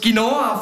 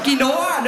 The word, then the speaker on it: Quinoa: Ich sehe eine Mutter, die mit ihrem Kind schimpft und aus Quinoa falsch gesagt hat. Es heisst Quinoa, nicht Quinoa. Nächstes Mal gibt es keine Cake Pops.